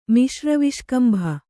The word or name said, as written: ಮಿಶ್ರವಿಷ್ಕಂಭ